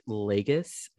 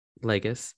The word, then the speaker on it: Lages: Uh, it says Everett Legus. I think that's how you pronounce it. L A G E S. – Sure. – Legus uh,